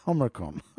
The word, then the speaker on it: Omicron: Omicron. Well, that's what they've got written here. Omricon. Omicron. I'm going to say it.